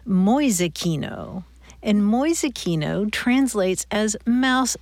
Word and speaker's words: Mäusekino: Mäusekino. – M-A umlaut U-S-E-K-I-N-O. Mäusekino. – Mäusekino.